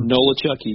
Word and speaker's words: Nolichucky: I'll pronounce it, what, Nolichucky River? – Nolichucky, yes.